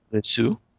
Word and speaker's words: Lisieux: Lisieux? – Lisieux? – No, Lisieux.